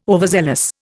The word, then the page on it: overzealous: Indian